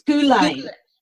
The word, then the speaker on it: Ghislaine: That's how Maria Farmer and Virginia say it. – Ghislaine. Okay. – Ghislaine. No, it's Ghislaine. – I think that's how my source was saying it too. Ghislaine.